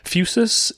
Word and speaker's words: Fusus: F-U-S-U-S. – That's how we've been pronouncing it, and I'm going to go with that because we mispronounce on the podcast all the time. So let's just go with Fusus.